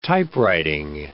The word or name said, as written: typewriting